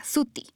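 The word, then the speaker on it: Swty: t y Suti.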